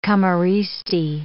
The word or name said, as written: camorristi